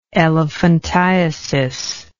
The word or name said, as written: elephantiasic